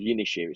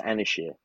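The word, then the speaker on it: Ynyshir: I look at it and think it's called Yinishir. It's not. It's Anishir. Because it's spelled Y-M-Y-S-H-I-R.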